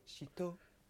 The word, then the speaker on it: shito: I tried the shito. – Shito. – Shito. Pronounce it properly.